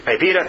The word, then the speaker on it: PIPEDA: You have another act, which I never remember how to pronounce correctly, but I'm going to go with PIPEDA. Is that right? PIPEDA, thank you, in 2008, that limits commercial use of personal data.